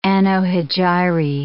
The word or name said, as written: anno hegirae